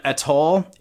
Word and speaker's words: Atoll: Kraken Atoll, A-T-O-L-L, am I saying that right? Atoll? – Sure.